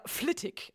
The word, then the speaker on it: Flitwick: Und Flitwick ist einmal der Name eines englischen Ortes in Bedfordshire, wird aber Flittig ausgesprochen, weil die Engländer mich verrückt machen wollen mit ihren merkwürdigen Namen, die ganz anders ausgesprochen werden, als sie geschrieben sind.